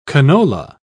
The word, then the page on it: canola: British English